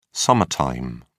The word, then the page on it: summer time: British English